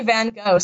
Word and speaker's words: Van Gogh: I just say Van Gogh, so you're going to deal with that. – Ooh. – See, that's the American pronunciation.